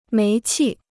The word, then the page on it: 煤气: méi qì